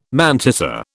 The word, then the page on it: mantissa: British English